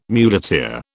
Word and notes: muleteer: British English

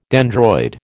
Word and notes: dendroid: American English

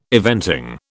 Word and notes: eventing: British English

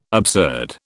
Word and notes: absurd: British English

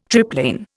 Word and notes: triplane: Indian

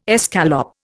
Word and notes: escalope: Indian

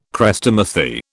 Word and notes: Chrestomathy: British English

